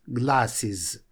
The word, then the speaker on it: glasses: Но я напомню еще, что окончание «ес» здесь читается буквой «is» – «glasses».